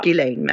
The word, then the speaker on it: Ghislaine: Man ska inte uttala S, det har jag lärt mig nu. Ja. Ghislaine Maxwell.